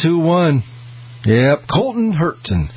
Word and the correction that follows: Herta: Hurtla. Get his name right, Colton Herta.